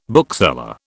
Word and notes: bookseller: British English

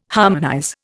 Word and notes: harmonize: Indian

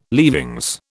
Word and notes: leavings: British English

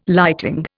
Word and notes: lighting: Indian

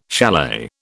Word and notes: chalet: British English

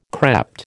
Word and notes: crept: American English

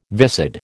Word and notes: viscid: American English

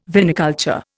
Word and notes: viniculture: Indian

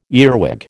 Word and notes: earwig: American English

